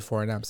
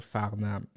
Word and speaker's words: Farnham: Parce que j'ai demandé comment on prononce Farnham, puis c'est Farnham, c'est pas Farnham.